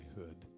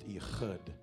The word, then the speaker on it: Ehud: But Ehud, I like to say hood, it's really more guttural, it'd be more correctly pronounced Ehud.